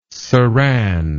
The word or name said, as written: serein